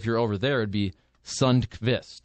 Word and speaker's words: Sundqvist: Like I got in a little argument at work once about Oscar Sundquist because he's called Oscar Sundquist. But I actually went to the pronunciation, and I do believe it should be Sundqvist. But I think it's the Americanized version of Sundqvist.